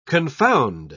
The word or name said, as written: confound